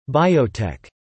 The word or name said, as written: biotech